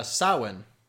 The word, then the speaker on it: Samhain: Samhain is actually how you pronounce it. – Samhain? – Yep. Because Celtic is stupid.